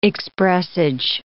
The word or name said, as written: expressage